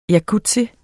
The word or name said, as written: jacuzzi